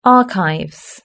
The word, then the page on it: archive: British English